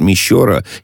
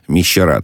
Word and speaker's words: Мещера: Да, интернет-энциклопедия все-таки дает Мещера именно на ее, ну и, соответственно, я делаю, да, ударение на этот слог. Хорошо, да, спасибо, принимается, так сказать. Буду Клепиковский район, Мещера, так, по крайней мере, говорят местные жители.